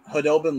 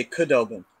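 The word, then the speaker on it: Khudobin: Go back to the bubble when Hudobin was having that really great run. There would be guys who would say Kudobin because it has the K in front of it, yeah.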